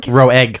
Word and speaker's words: Roeg: I like to say Roeg. Roeg.